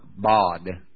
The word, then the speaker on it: bad: Did you know that the Hebrew word for this is the word B-A-D? Now, we would say bad. But the A in Hebrew would have been pronounced ah, like father, bod.